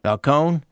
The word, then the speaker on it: Falcone: Would that be the correct pronunciation? – Falcone.